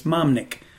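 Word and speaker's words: Mahmnik: It's very simple. It's M-A-H-M-N-I-K. It's Mahatnik. My brother's name is Nick, and the first part was for my mom.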